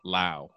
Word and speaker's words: Lowe: Brandon rhymes with now. Lau.